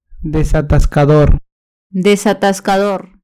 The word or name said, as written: desatascador